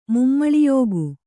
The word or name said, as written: ಮುಮ್ಮಳಿಯೋಗು